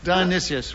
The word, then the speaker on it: Dionysius: I think it was Dionysus, or you might pronounce his name differently. – Paul Challenge. – Hesitation. – There was a hesitation, yes. – How would you pronounce his name? – Well, I would pronounce his name Tickled Pink. – Dionysius, right.